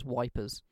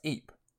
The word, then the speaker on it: Ypres: which is spelt Y-P-R-E-S. The British soldiers refer to it as wipers. – Yeah. – It's a fucking bastard of a word to say. – I think it's just pronounced Ypres.